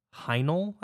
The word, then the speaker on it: Hajnal: And this is a song called Hajnal, I believe it's pronounced. H-A-J-N-A-L.